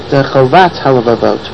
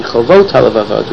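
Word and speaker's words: Chovot HaLevavot (חובות הלבבות): For some reason, people pronounce it Chovat HaLevavot, which is duty of the heart, but apparently it should be Chovot HaLevavot, which is duties of the heart. It should be plural.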